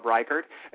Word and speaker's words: Reichard: While Robert there, Robbie, is looking over his list, everybody give us a call if you want to talk to Mr. Robb Reichard.